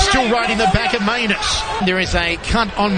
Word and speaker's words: Mainus: And the fighter's name, I'm going to spell it for you, is Danny Maianus. M-A-I-N-U-S. Maianus.